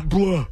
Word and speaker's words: Blough: B-L-O-U-G-H. Pronounce that. – Very appropriate. Blah. – Blah.